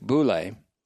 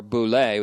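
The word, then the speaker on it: boule (βουλή): The word that is translated as counsel there, or decision, or determination, or better, intention, is B-O-U-L long E in the Greek, boule. And the intention of God is his unstoppable intention. Boule also has the meaning, or boule with the accent on the second syllable,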